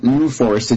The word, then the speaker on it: NUFORS: Do you pronounce it like NUFORS or? – Yeah, I pronounce it NUFORS.